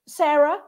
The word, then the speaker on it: Sara: Okay, well, first of all, if you're listening and you can't see the banner, my name is Sarah, but it doesn't have an H on it, most important. So it's spelled S-A-R-A. So contact me on, so I'm Sarah without an H, Ellis.